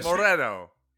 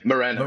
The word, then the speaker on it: Moreno: You also mispronounced my surname, but that's fine. We'll skip it. – Oh, let's get that right. – Fresh. Moreno. – Is it Moreno? – Moreno. Moreno. Yeah. Spanish. Moreno, yeah.